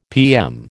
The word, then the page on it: PM: American English